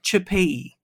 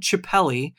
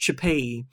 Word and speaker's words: Chipelli: Loidel Chipei. That's what it's spelled Chipelli, but it's pronounced Chipei.